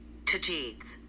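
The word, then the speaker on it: Tagetes: T- Let's see what uh how does how does Siri gonna pronounce this? Hold on. Uh Tajids. Tajids? This says Tajids.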